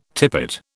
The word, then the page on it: tippet: British English